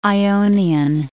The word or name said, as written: Ionian